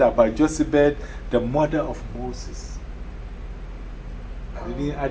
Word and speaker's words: Jochebed: The next, four, this name, Jochebed, am I pronouncing it well? Jochebed. So Jochebed, right? Okay, he said, yeah, Jochebed, yeah.